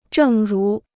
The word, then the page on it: 正如: zhèng rú